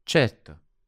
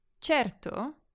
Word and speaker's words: certo: With the word certo, we've got the Italian R, the R, in front of a consonant, a T in this case. And this can be tricky to pronounce. One tip I have is to imagine that it's a D sound. So it would be like cedto. If you speed it up, it will start to sound like the Italian pronunciations.